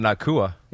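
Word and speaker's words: Nacua: Nakua? – Yeah, we all know about Nakua.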